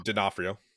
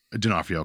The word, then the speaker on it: D'Onofrio: Yeah, I've heard it D'Onofrio. – D'Onofrio, okay.